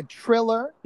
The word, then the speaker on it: Triller: Isn't that what it, or a triller? – Is that still a word? Is that word still usable? – Because it's spelled T R I L L E R. Triller.